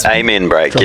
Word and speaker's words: Amen Break: You know, the Armin Break is a very, very common drum. Help me out here, producer. – The Amen Break. Yeah. – I'm pronouncing it with a Kiwi accent, all right?